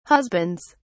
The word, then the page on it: husbands: British English